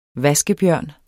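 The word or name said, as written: VASKEBJØRN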